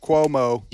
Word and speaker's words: Cuomo: Last name pronounced Cuomo.